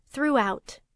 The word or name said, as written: throughout